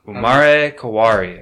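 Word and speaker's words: Umarekawari: Umare Kawari.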